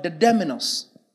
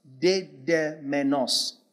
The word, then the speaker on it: dedemenos: The word bound is the word dedemenos. Dedemenos. The way it's pronounced.